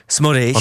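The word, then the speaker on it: Szmodics: taková trošku říčka pro komentátory, jak se vyslovovat, tedy jak ho mají vyslovovat. – Takže Smodič, jo? – Smodič. – On má maďarské předky, víš? – Má, tak to jméno trošku to evokuje, no.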